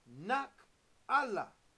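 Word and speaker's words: Nak Allah (נחלה): On the Hebrew side of your Strong's Hebrew Greek Dictionary, and it is pronounced Nak Allah. And it is so significant that you hear the word Allah.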